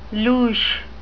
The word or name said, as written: louche